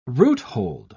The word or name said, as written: roothold